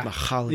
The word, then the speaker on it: Mihaly Csikszentmihalyi: Have you ever read the book Flow by the author, I'll never pronounce it correctly, but it's Mihaly Csikszentmihalyi. – You actually like went deep on the H there. – Yeah. – Mihaly. – Yes, I got it there.